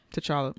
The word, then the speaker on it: T'Challa: And another thing that's faulty about that comparison, because Martin Luther King, I'm assuming, would be T'Challa. That's how you say his name? – T'Challa.